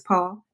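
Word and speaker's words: Paule: Is it Paul? Is it just Paul, or is the E pronounced? – It's Paul. – It is Paul.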